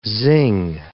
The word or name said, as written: zing